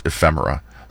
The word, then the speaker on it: ephemera: ephemera. So I was just worried. – I was like, did I say it right?